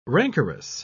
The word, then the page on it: rancorous: British English